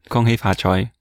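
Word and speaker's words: Kung Hei Fat Choi: How do you say it in a not terrible English accent? – Kung Hei Fa Choi.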